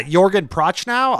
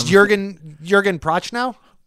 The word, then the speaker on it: Jürgen Prochnow: Sounded very good. It sounded like something you had like practiced and looked up. I was like, oh, that's a good pronunciation. – I think it's Jürgen Prochnow.